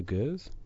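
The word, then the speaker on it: gueuze: And the spelt was the third one I've had, and it smells like a gurs. That's the proper pronunciation. Gurs. No big deal.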